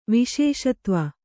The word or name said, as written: ವಿಶೇಷತ್ವ